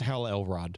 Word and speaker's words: Hal Elrod: Hal Elrod. He's the Miracle Morning guy. – Come again? – Yeah. I think I said it right. Maybe I've put it. No, yeah, it is. It's Hal Elrod.